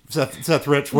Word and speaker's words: Seth Rich: So it's just like what happened with Seth Reich. Seth Rich.